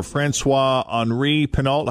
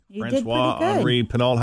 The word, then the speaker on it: François-Henri Pinault: And we mentioned earlier in the aftermath, French billionaire François-Henri Pinault. How did I do, Fritchie? François-Henri Pinault. How would you pronounce that? – I haven't seen it, but I think you sound good.